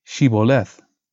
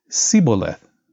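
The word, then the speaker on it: Shibboleth: When he said, "No," they said to him, "Say, Sheboleth." And he said, "Siboleth," for he could not pronounce it right.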